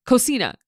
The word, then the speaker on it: cocina: Cochina. Every time I say this on the podcast, I panic. Cocina. Cochina. Hold on. Okay, I just watched multiple YouTube videos, and I believe the correct pronunciation is cocina.